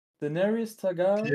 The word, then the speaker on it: Daenerys Targaryen: name is i i usually know it it's um Tenera Stagarian. I think I've so, said it wrong. it's something with Stargarian, I know. I'm, I, to be honest, I'm not so into Game of Thrones. I, I never watched it really. But I think her name is Daenerys Ta. Yeah, it's Daenerys Targaryen.